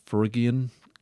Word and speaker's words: Phrygian: A Gordian Knot is kind of a mythological, what's apart from the legend of the, how do you say that, Perigian, no, oh jeepers, Phrygian, Gordium, associated with Alexander the Great, but it's a metaphor.